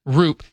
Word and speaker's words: Roop: So after the game, the pitcher, Landon Roop. – See? I told you. By the way, during the break, I was like, it's spelled route, but it looks like it's spelled in a way that's intentionally misleading.